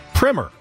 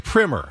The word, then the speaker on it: primer: Yeah, when you have an elementary text on something, like Jeff Passan writing about the offseason, it is pronounced primer. If you have the first coat of paint on a house, that's primer. – The primer. – All right. – But a text, like a textbook or a writing on a subject, that is pronounced primer. And that's American English.